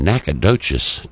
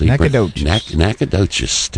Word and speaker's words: Nacogdoches: Coming out of Nacogdoches, Texas. – Nacogdoches, Texas. – I think he spells it out for you. – He does and pronounces it. Yeah, Nacogdoches, Texas.